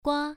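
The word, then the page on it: gua: tone 1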